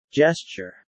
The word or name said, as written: gesture